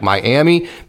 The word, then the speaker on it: Miami: The funny one was he's from Florida and we have a town in Oklahoma that is spelled M I A M I, like Miami, but it's actually pronounced Miami.